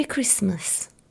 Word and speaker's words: Christmas: We don't pronounce the T in the middle and we don't exaggerate the I at the end. We say Merry Christmas.